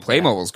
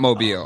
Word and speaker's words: Playmobil: It's kind of like Playmobil's great. – Is that how you say it? – Isn't it? – It's Playmobil. It's not Mobile.